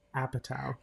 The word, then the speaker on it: Apatow: Apatow.